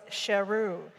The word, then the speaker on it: Sheru: It was Sheru, based on the Hindi word sher, meaning lion.